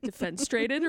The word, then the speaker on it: defenestrated: Like, I'm very eager to, like, ooh, does Taylor get defenestrated or whatever? Is that the way you pronounce that word? – Yes. Amazing.